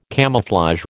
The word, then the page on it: camouflage: American English